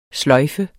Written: sløjfe

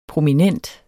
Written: prominent

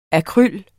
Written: AKRYLET

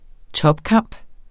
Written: topkamp

